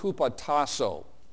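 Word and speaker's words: hupotasso (ὑποτάσσω): It's pronounced hupotasso, and you could make that one of your favorite words.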